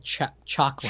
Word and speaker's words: Chakram: And then and she grabs the real Shakma on their way out. I still hope I'm saying that right. Let me know. – It's called, I found it. It's called Chakra. – Chakra. – C-H-A-K-R-A-M.